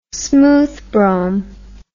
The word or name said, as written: smooth brome